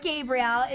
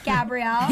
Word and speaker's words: Gabrielle: E-L-L-E. – It is not Gabriel. It's Gabrielle. I'm a female. 2 E's and 2 L's. Thank you.